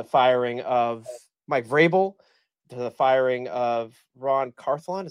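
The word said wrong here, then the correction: Ran Carthon: Is that how you say his name? I'm not sure, 100% sure how to say his name. The GM. – Ran Carthlon. And now Ray Carthlon. Sorry. – Ran Carthlon. – Ran Carthlon. Thank you. Thank you, Manish.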